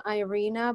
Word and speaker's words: Irina: I say my name, Irina, but I like your way better.